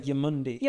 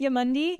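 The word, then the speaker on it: Eumundi: So yeah, we got to Umundi. Which is right off. – I say like Yamundi. – Yamundi. Yeah. – But it's spelled with an E.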